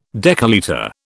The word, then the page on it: decalitre: British English